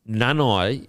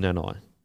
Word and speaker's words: Nanai: Apparently, Nanai is not pronounced Nanai. Apparently, it's Nana.